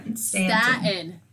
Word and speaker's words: Staten: Staten. – I can't say it. You say it. – Staten Island. – There you go. So it was from CNN Business. Amazon fires warehouse worker who leads the Staten Island warehouse walkout.